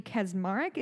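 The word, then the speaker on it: Kacsmaryk: There is a judge, his name is, I believe, Matthew Kaczmarek is how you pronounce it. It's a very Polish name.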